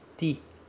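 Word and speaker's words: ti (パーティー): Aqui no katakana do party, a gente tem um T seguido de um I pequeno. Vou colocar isso aqui para você ver. Isso é um T. Isso aqui é um I pequeno, ok? Isso se pronuncia ti.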